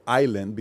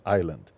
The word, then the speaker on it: island: I can't pronounce the word island because at that time the big show was Gilligan's Island. Okay. So I would say Island. I would ask the teacher, why is there an S here? We don't need an S. Just put I-L-A-N-D.